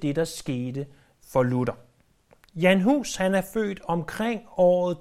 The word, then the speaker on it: Jan Hus: Eller som man siger det på engelsk, om end han ikke var englænder, John Huss. Men Jan Hus er den nok mest korrekte udtale, eftersom det er sådan, man stadig udtaler hans navn, der hvor han kom fra.